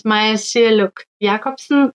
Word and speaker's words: Maya Sialuk Jacobsen: My name is Maya Sialuk Jacobsen, and I am from Northwest Greenland.